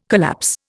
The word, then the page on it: collapse: Indian